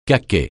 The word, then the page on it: caquet: francais